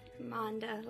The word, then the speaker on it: Monda: Monda, like Honda with an M.